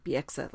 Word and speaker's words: Bieksa: I just want to point out, I did not know that Kevin Bieksa actually, Bieksa, let's try to say his name correctly.